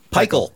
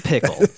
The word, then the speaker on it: Pickle: Pikel. – I was really surprised she pronounced that right away. – She looked at his name and went, Pikel. – I was like, no, that's Pickle. – Totally Pickle.